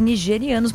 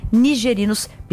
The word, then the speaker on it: nigerinos: E falamos aqui nigerianos, mas na verdade a pronúncia é correta, né? No caso, são cidadãos, né, nigerinos.